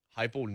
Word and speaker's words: Heupel: Heupel needs that first syllable in his name to live up to some. He needs hype to be there.